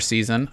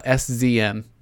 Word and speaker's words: SZN: All right, so I know this Ron Abbasi song, Dreamer Season. I believe when I first played it on this show, like months and months ago, I said SZN. Just like so missed the mark it's pronounced season.